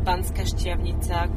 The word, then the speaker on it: Banská Štiavnica: Historical towns, like for example, Levoča, Banská Štiavnica, Košice.